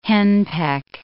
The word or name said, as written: henpeck